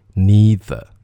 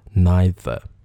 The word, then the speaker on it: neither: z neither neither，当然美音当中它会念成 neither。这都可以，因为我念的是英音，所以是 neither。